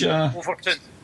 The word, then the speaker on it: Overtoun: Overtoun. – What is it? – Overtoun. – Overtoun. Overtoun. – Aye. Overtoun. – Aye. – It's spelled O-V-E-R-T-O-U-N, Overtoun.